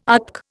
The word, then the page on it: ATC: Indian